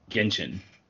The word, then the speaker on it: Genshin: And the nominees were Yakuza Like a Dragon, Genshin? How do you say that? – Genshin.